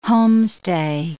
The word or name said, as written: homestay